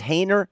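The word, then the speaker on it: Haener: Jake, is it pronounced Hayner? – Yes.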